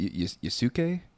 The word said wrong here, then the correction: Yasuke: Yasuke. Yasuke's trash, but okay.